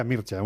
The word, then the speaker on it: Mircea: Mircea, sí, se pronuncia Mircea, uno que es muy friki de todos los rumanos, ya lo sabéis.